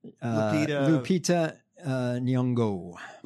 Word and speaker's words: Lupita Nyong'o: Lupita Nyong'o. – Lupita Nyong'o, great actor, you know, but has to deal with this.